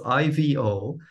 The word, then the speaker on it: Ivo: So it's I V O.